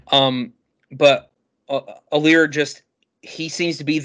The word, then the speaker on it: Allar: I think that this team, even though they're at 9.5 wins, I think that if Drew Allier, man, I heard the correct pronunciation of his name, and it's not Allar. That is not right. Oh my gosh, it doesn't matter. Drew Allar? – Allar, that might be it.